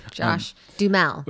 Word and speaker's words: Duhamel: Josh Dummel. – Yeah, Dummel. I don't think Dummel's right. – Josh Duhamel.